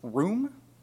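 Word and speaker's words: room (רום): The Hebrew word for exalt is room. That's spelled R-U-M, but not pronounced like R-U-M.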